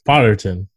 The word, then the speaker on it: Potterton: So uh technically there's directors for each segment, but they were overseen by our head fellow here, Gerald Rotterton, Rotterton, Potterton. Holy shit! yeah, you gotta say it right.